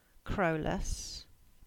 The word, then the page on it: Crowlas: English